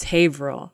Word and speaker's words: Haverhill: And for those of you at home, it's spelled like Haverhill, but it's Haverhill.